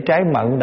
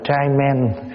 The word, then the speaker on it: trái mận: Đi về Việt Nam mà thấy người ta để trái mận đọc là trai men.